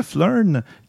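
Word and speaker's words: Phlearn: Parce que Flurn, qui s'écrit PH euh, Learn, comme dans Photoshop Learn.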